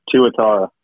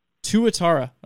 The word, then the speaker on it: Tuatara: Tuatara? You must know. How do you say that in the Australian Baseball League? – Yeah, Tuatara. – Tuatara.